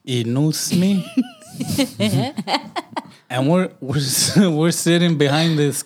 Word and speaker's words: INOUCME: I say inusme. Yeah. – Yeah, inusme.